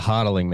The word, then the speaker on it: Hotaling: but Hotaling seems pretty straightforward to me. – Yeah. Hotaling, man.